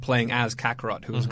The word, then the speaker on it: Kakarot: Kakarot.